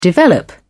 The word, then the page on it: develop: British English